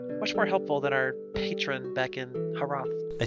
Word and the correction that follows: Haroth: I think the H is silent, still. – Roth, sorry. It's habits.